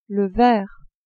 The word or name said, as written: verre